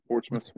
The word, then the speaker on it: Portsmouth: Portsmouth.